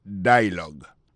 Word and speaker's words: dialogue: Comme n'a pas mis des accents, sous bel accent en anglais, sous belle prononciation en anglais, le mot dialogue a gagné deux syllabes là-dedans. Daily dialogue, alors, il y a pesé plus sur le premier syllabe là. Daily dialogue.